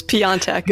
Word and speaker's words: Piontek: So it's Piontek. – Piontek? – It's Polish. So, yeah.